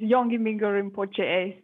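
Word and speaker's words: Yongey Mingyur Rinpoche: Did I pronounce that completely wrong? – Yes, thank you, Sandy, for introducing. Yes, my teacher name is kind of long. It's Yonge Mingyur Rinpoche, so it's good. It's close enough.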